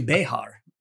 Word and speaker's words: Bexar: It's actually Behar, if you want to pronounce it properly. But Bear is the Anglo version of it.